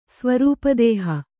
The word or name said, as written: ಸ್ವರೂಪದೇಹ